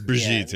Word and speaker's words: Brigitte: Isn't it Brigitte? – It's Brigitte, yeah.